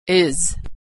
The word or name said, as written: is